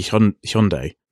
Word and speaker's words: Hyundai: So traditionally, we've always called it Hyundai. And I know in America, they pronounce it a bit weird. But I think it's supposed to be Hyundai. – That makes sense. Hyundai. Yeah. Yes, because of a yun.